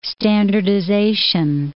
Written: standardization

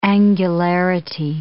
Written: angularity

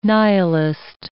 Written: nihilist

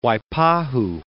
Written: Waipahu

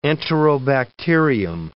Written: enterobacterium

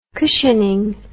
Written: cushioning

